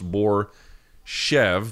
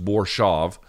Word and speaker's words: Borshchev: They pronounced it Borshov. It looks like it's pronounced Borshev, but I guess it's just Borshov.